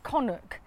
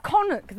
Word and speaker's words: Canuck: Because I was nicknamed Connie, my name was Connock. And it's spelt Canuck.